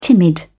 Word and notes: timid: British English

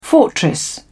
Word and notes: fortress: British English

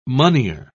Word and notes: moneyer: British English

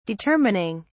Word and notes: determining: British English

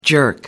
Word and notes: jerk-off: American English

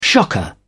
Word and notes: shocker: British English

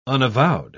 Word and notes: unavowed: British English

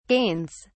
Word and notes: gains: British English

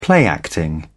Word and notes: play acting: British English

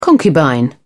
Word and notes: concubine: British English

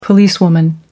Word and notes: policewoman: American English

